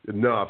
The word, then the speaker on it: Knoff: He knows what he's doing, and he tweets at Dr. Howie, K-N-O-F-F. Dr. Howie, K-N-O-F-F. It's pronounced, as I have learned over the years, with a silent K, enough.